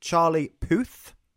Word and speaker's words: Charlie Puth: This is Charlie Puth.